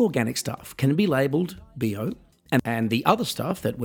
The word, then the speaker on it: bio: Now, here in Germany, the word for organic is bio. It's actually spelled bio, B-I-O, but it's pronounced like the short form for the expression body odor,